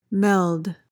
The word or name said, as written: meld